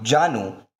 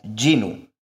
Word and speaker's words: Janu: Kavita recalls that she had wrongly pronounced the word Janu as Jinu.